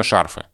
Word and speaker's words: шарфы: А скажешь, что, например, шарфы, и мало кто обратит внимание, при том, что нормативно шарфы.